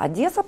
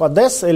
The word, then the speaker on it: Одесса: А говорить, а говорить правильно, знаешь, как Одесса или Одесса? Там же, они же обижаются. Я говорю, Одесса. Не Одесса, а Одесса.